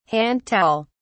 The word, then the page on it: hand towel: British English